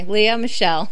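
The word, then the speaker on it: Lea Michele: Oops, sorry. Leah Mitchell. – Michelle. – Sorry. Leah Michelle. I've been saying it wrong this whole time. I've always thought it was Mitchell.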